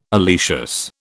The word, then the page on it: oleaceous: British English